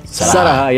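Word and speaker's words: Sarahah: E o Renato Doná mandou, falou que eu tava confiante que o, o Sa Sarahá, falei certo? – Sarahá, ia durar falou.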